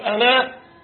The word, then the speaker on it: أنا: الالف اللي بعد النون يا جماعه لها ايه؟ صفر مستدير ولا قوسين مفتوحين؟ طيب صفر مستدير ده معناه ايه؟ تكتب ولا تنطق حال الوصل، شرط لابد، انما في حال الوقف تنطق وتكتب. وانا لو هنوقف ماشي كده، لكن لو هنوصل هنقول وانا اعلمه، مش كده؟ يبقى الالف بتاعت انا اللي بعد النون ده ايه؟ تنطق وقفا لا وصلا.